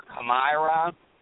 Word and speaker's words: Kamira: It's K-A-M-I-R-A. – K-A-M-I-R-A? – Uh-huh. Kamira or Kamira.